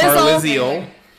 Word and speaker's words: Carlisle: Carlisle.